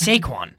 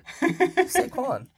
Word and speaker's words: Saquon: Do you say that with two syllables? – What? – Saquon. It's Saquon. – Saquon. – Am I crazy? – No, nah, he's using two syllables.